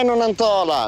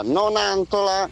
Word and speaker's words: Nonantola: Non è Nonantola, Nonantola. – Sì. – Si dice Nonantola.